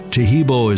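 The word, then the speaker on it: Taheebo: Tejibo is spelled T-A-H-E-E-B, like boy, O, then the word tea, and then the word club.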